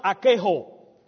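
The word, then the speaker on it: archeho (ἀρκέω): That word in the Greek is pronounced archeho. A-R-K-E-H-O. Archeho.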